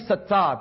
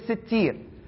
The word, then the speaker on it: Sittir: Some people say the name Sattar, but the correct way to say it is Sittir.